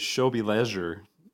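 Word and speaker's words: W żłobie leży: In Polish, you pronounce it as Shobi Leisure.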